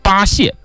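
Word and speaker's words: 巴解: 大禹来到江南治水，派壮士八蟹，八是这个巴蜀的巴，蟹是蟹广元的蟹啊，那个就是解决的解，它的一个多音字蟹。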